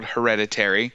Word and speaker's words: Hereditary: So today, we watched a movie that I apparently can't pronounce. It is called Hereditary. I've been calling it Heredity the entire week.